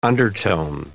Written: undertone

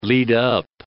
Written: lead up

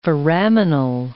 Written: foraminal

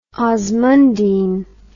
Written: osmundine

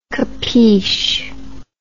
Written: capeesh

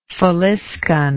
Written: faliscan

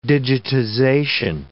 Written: digitization

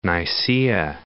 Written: Nicaea

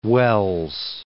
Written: Welles

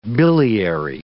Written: biliary

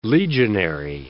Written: legionary

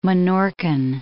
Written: Minorcan

